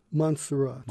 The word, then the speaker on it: Montserrat: And is it pronounced Montserrat? – Montserrat.